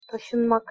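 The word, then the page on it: tasinmak: Turkish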